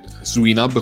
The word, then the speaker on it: Swinub: Allora, parto quindi dicendoti che il nome è Swinab, come, pro- come pronuncia, non c'è dubbio su questo,